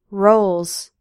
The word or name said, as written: rolls